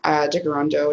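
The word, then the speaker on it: Tkaronto: As I said earlier, I'm on Anishinaabe Ojibwe, and so I am in Jigarondo, which is the Mohawk pronunciation for Toronto.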